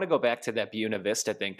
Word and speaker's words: Buena Vista: It's spelled like Buena Vista, but since there's a lot of really white people here, they pronounce it Buena Vista because they don't give a shit. – I was going to say, I think you pronounced it wrong. – No, they call it Buena Vista because it's supposed to be like beautiful view, beautiful Buena Vista.